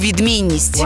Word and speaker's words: відмінність: Сьогодні наша передача про слово «відмінність». Наголос на слові «відмінність» падає на другий склад.